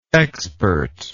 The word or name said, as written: expertness